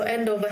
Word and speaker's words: Andover: You guys are in, I hope I'm saying this right, Andover, Hampshire, which is about an hour outside of London. Is that correct? – Yeah, that's right. So Andover, Hampshire.